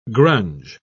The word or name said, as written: grunge